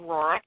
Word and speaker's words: Rourke: It's Catherine Rourke, R-O-U-R-K-E.